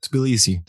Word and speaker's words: Tbilisi: Is it Tbilisi? – Yeah, Tbilisi.